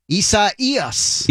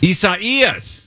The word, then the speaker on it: Isaias: How do they pronounce it? Isis? – It's the easiest way I've done it is go Isaias. – Isaias. All right. Isaias. – Accent on the E.